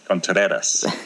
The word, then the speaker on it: Contreras: And Contreras. – Contreras. – That's pretty good.